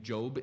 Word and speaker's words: Job: just like the name Job is Job, not Job.